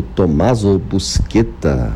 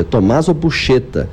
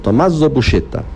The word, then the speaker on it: Tommaso Buscetta: A pronúncia não é essa, tá, gente? É Tommaso Buscetta. E a forma como se escreve, quem lê com as normas de pronúncia em português, lê uma outra coisa, né? Mas a pronúncia italiana é essa, Tommaso Buscetta.